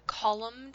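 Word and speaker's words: kolam: Have you ever heard of the column designs that they make in India with chalk? – No.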